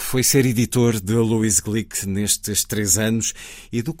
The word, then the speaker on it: Glück: Luísa Glick, eh, pronuncia-se assim porque o apelido é de origem húngara, ela é uma imigrante, os pais foram imigrantes húngaros nos Estados Unidos,